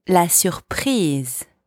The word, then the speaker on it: la surprise: It's spelled the same way as in English, although you pronounce it differently. La surprise. La surprise.